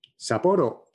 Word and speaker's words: Sapporo: Sapporo, yeah, in Hokkaido.